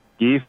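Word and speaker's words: Kyiv: Kiev, digamos, sería la pronunciación en ucraniano. Kiev es la pronunciación en ruso a la que estábamos acostumbrados.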